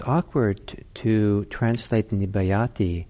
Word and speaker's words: nibbayati: So nibbayati, N-I-B-B, long A, Y-A-T-I.